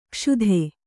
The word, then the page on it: ಕ್ಷುಧೆ: kṣudhe